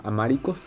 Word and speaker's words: amárico: Lo, la sobra le enseñó el am, Amárico, creo que se pronuncia. amarico. – amárico. Amárico, que es pues el lenguaje que se utiliza en Etiopía.